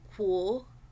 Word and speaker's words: kwo: So this is pronounced as kw, kw, kw. An example would be kwo.